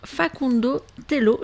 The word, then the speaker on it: Facundo Tello: L'arbitrage qui a fait couler beaucoup d'encre après le match Argentine contre l'Égypte, hein, qui a été confié à l'Argentin Facundo Tello.